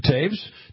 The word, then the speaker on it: Toews: How do you pronounce that guy's name from UND? I always have trouble with it. – Jonathan Toews? – Toews, yeah.